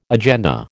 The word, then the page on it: agenda: American English